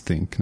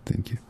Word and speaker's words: ThinQ: LG still doesn't seem to understand why we think that it should be pronounced think, not thank you.